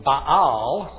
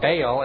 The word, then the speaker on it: Baal: Now, the other one we find out mostly in Scripture, and he occurs in these ancient tablets as well, is Baal, pronounced in Hebrew, Baal. So you may hear me pronounce it Baal or Baal, as we usually do in English.